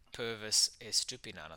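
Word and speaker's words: Estupiñán: Uh, Pervis Estupinan, I think is how you say his name. That is correct, yes. Or Pervis getting the assist on his debut.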